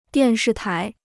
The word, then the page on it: 电视台: diàn shì tái